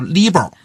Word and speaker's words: LIBOR: 我有一年在外面上课，跟同学们讲这个伦敦银行间同业拆借读 LIBOR，然后呢，下了课有个学生跑过来跟我说，老师你这个发音不对，应该读 LIBOR，它是海归啊。 – 我想我这人知错能改啊。我到了下堂课我就读 LIBOR 了然后后来呢，读了 LIBOR 之后，下了课又一个学生跑过来说他也是海归，应该读 LIBOR。